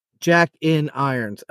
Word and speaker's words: Jack in Irons: But tell us about the Jack and Iron. – Oh, can you help me with the pronunciation? Jack and Iron? – Jack dash in dash irons. – Jack in irons. Okay, like guns in roses.